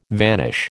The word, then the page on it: vanish: American English